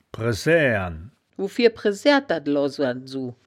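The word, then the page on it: bresääåren: Wittlicher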